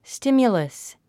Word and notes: stimulus: stímjuləs; スティミュラス

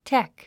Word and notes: tech: ték; テェック

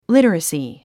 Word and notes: literacy: lítərəsi; リタラスィ

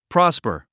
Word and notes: prosper: prάspə r; プラスパー

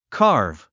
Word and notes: carve: kɑ’ː r v; カーブ

